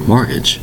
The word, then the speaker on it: mortgage: Mortgage, two distinct syllables, spelled M-O-R-T-G-A-G-E. Now you'll notice, when I pronounce the word mortgage, there is no T pronounced. Therefore, the T in the spelling is totally silent. It's pronounced mortgage. M-O-R-T-G-A-G-E is the correct spelling, but the T remains totally silent.